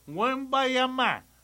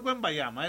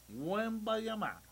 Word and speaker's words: Wembanyama: dicen los franceses, que se pronuncia Wembanyama. – Ah, bueno. – No es Wembanyama, es Wembanyama.